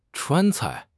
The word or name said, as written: Chuan1 cai4